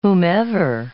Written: whomever

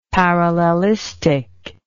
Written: parallelistic

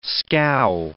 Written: scow

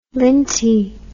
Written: lintie

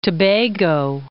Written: Tobago

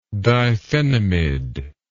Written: diphenamid

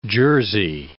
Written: jersey

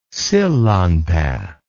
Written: sillanpaa

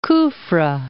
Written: Kufra

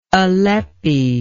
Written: alleppey

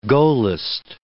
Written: Gaullist

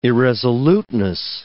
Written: irresoluteness

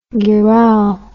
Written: gherao

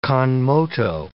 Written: con moto

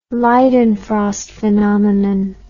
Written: Leidenfrost phenomenon

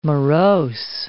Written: morose

